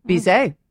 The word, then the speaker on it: Bizet: Bizet.